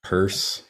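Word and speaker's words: Peirce: But the other thing too is Pierce or Pierce. It's Pierce. Purse. Purse. Thank you. Yeah. It's, it's such a strange pronunciation. It's not intuitive at all.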